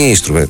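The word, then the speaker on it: Nystrup: Θέλω να πω ότι ευτυχώς που είδαμε αυτό το βίντεο, γιατί ακούσαμε τον Δανό κύριο να προφέρει το όνομα του Νίστρουπ, το οποίο είναι Νίστρουπ, που έχονται δύο Ι.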